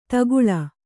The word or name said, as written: ತಗ್ಗಾಳು